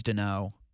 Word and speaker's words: Daigneault: Did I pronounce it Dano right? D-A-I-G-N-E-A-U-L-T. – That sounds correct. – I think it's Dano.